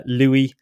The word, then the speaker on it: Lewis: Louis, I think it's spelled as Lewis, but I think he wants to be called Louis.